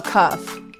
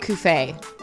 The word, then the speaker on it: Cuffe: Oh, Cuff. – Cuff. – Yeah. – Why did everyone call him Cuffé? – Well, it's kind of spelled like that. – Yeah. – So it's just that simple, Cuff? – Yeah.